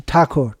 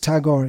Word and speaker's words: Tagore: Tagore is Brahmin. Thakur. It's a Brahmin name. Pronounced Tagore, the anglicization.